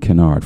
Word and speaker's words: Kinard: Armand Kennard. First name, A-R-M-O-N-D. The D is silent. And Kennard, K-I-N-A-R-D.